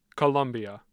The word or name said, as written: Columbia